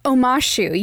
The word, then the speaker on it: Omashu: Omashu.